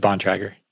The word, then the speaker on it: Bontrager: It's Bontrager. Bond Tracker.